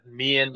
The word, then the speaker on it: Meehan: At Dan Meehan.